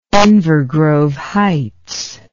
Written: Inver Grove Heights

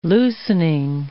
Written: loosening

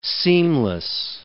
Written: seamless

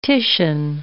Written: Titian

